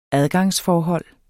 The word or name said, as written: adgangsforhold